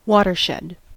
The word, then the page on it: watershed: American English